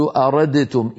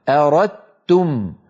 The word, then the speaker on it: أردتم: فعندما يأتي دال ساكنة بعدها تاء ندغم الدال في التاء فيصبح النطق بتاء مشددة ولا يبقى للدال أثر في النطق. هكذا أردتم، أردتم النطق تاء مشددة ولا يبقى من الدال في النطق شيء، تبقى الدال في الخط فقط.